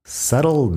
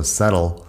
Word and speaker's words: settle: Fíjate que yo cuando digo settle no estoy pronunciando la T fuerte, pero podrías, un inglés de Reino Unido podría decir settle down o algo así con la T más fuerte. Es doble T.